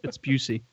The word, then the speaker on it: Busey: He spelled it out phonetically, and it's Busey.